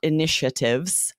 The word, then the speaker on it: initiatives: This is tricky pronunciation. It's the A is soft, initiatives, right?